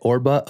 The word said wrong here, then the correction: Orbea: O-R-B-A. – Orbea. Yeah, the Spanish. Orbea.